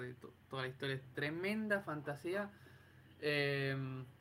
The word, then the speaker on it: Fantasia: Em, y, bueno, Fantasía es una peli, bueno, en inglés le pronuncian Fantasia.